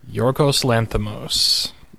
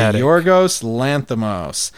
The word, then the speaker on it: Yorgos Lanthimos: Yorgos Lanthimos. – Directed by Yorgos Lanthimos.